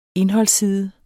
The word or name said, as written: indholdsside